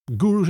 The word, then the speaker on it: Guruz: Het is dat Gurus is de uitspraak. – Gurus, is helemaal, helemaal goed. – Met een zet op het eind.